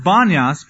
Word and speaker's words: Banias: It's pronounced Banyas because the Arabs couldn't pronounce the P sound. The god Pan or Paneas, whom that region is named after, when the Arabs came in and took it over, they couldn't pronounce the P, so they called it, instead of Paneas, they called it Banias. It became an Arabic sound.